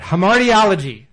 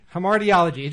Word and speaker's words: Hamartiology: Hamartiology. Hamartiology.